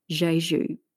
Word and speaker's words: Jeju: And if you'd like to look at this place, it's called Jeju, pronounced J-E-J-U.